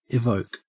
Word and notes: evoke: Australian English

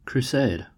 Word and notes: crusade: American English

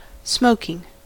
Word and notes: smoking: American English

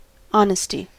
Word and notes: honesty: American English

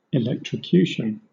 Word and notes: electrocution: British English

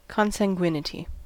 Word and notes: consanguinity: American English

